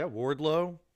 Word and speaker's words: Wardlow: Wardlow? – Yeah, Wardlow.